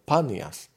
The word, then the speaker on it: Panias: V Izraeli je lokalita na severe, už takmer teda pri hraniciach e, so Sýriou a to je tzv. panias, alebo banias niekedy vyslovený.